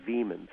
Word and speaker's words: Viehman: Veman, Theodore Veman. I don't know if that name means anything to you. It's spelled V-I-E-H-M-A-N, and he was the head of the Tulsa Little Theater.